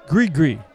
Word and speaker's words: gris-gris: gri-gri, spelled G-R-I-S-G-R-I-S, but pronounced gri-gri, for a price. Gri-gri,